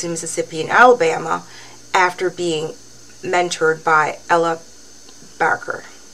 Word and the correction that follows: Baker: Am I saying that right? I can't see. – Baker. Ella Baker. – Is it Baker? Okay. – Yeah, Ella Baker.